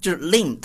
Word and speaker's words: limb: 对对对、okay、就是 limb，大家注意这个发音啊， limb 就是 M 和 B 在一起的时候、这个 B 一般都不发音，不发音，跟中国人不一样。